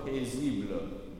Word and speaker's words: paisible: Good, so the last syllable is a schwa, so it's like the word book. Paisible.